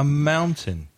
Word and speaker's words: mountain: Now, the pronunciation is different from the spelling. The spelling, M-O-U-N-T-A-I-N, but the first vowel sound is ow, like cow or now, so it's moun, moun, and T-A-I-N, tin, mountain, mm-hmm.